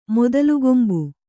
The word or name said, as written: ಮೊದಲುಗೊಂಬು